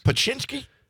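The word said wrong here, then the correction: Pączki: Paczynski? – No. – Puchki. – Puchki.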